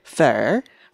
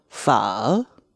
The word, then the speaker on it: pho: because the British like to say fur. – Fur. Okay, but you know, I've heard some people get really pedantic and say that that's the way it's meant to be pronounced. – Well, if you want to like really impress a Vietnamese speaker, you would say pho.